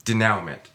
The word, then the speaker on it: denouement: But all right, seriously, how do you say it? – Denouement. – Okay, so denouement.